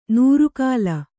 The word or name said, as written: ನೂರುಕಾಲ